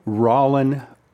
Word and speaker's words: Rollin: A guy by the name of uh, Joe Rollin Porter. Okay. Okay. It's, pronou- uh, it's spelled R O L L I N, but it's pronounced Rollin.